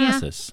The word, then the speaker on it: Manassas: So the company's headquarters in Manassas, Virginia. – Manassas. Okay. – I almost slaughtered that. – It's probably right. Manassas.